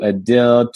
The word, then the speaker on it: Adil: For me, when I started school and I introduced myself, most folks couldn't say my name, which is Adil. And so it morphed over the years from Adil to Adil to Adil.